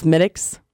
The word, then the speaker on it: Smithwick's: Smittix, which it looks like Smithwick's, but it's pronounced Smittix.